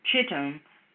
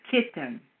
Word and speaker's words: Kittim: It looks like it's Chittim, but they pronounce it as Kittim.